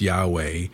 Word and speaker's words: Yahweh: We've been talking about God's name, which is in Hebrew, Y-H-W-H in the Hebrew letters, and probably pronounced Yahweh.